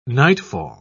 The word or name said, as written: nightfall